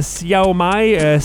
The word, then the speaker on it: Xiaomi: En 2010, Xiaomi, ça c'est la prononciation du mot que vous avez peut-être vu appelé X-I-A-O-M-I. Les gens vont dire Xiaomi, Xiaomi, c'est Xiaomi apparemment.